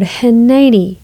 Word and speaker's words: Hineni: On our podcast today, we are going to talk about the word Hineni, which is a Hebrew word for the expression, "Here am I." It's spelled H-I-N-E-N-I, and it's represented by Strong's H2009.